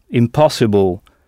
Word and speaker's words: impossible: Ça s'écrit donc comme en français, mais vous avez sans doute remarqué qu'en anglais, I suivi de M se prononce im, impossible. Tout simplement parce que le son nasal « un » n'existe pas en anglais.